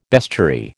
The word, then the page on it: bestiary: American English